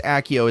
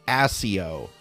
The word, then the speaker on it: Accio: They said Accio, and it's not pronounced Accio, it's pronounced Accio.